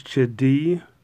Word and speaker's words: Chidi: And I think it's Chidi, which is, hence, is how you pronounce his first name, which, of course, leads to his terrible nickname, Chidi Bang Bang.